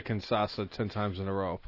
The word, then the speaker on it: Kinshasa: Uh, he hit what three sh- uh, Kinshasa. Kinshasa.